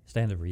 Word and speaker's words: Standefer: Did I say that right? – Standefer, yes. Standefer. Yeah, that's right.